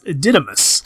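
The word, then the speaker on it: DE DE MOUSE: From DD Mouse, or as I've heard it's pronounced, Didymus.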